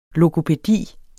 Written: logopædisk

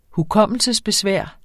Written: hukommelsesbesvær

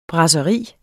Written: brasseri

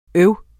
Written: øv

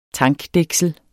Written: tankdæksel

